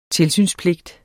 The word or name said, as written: tilsynspligt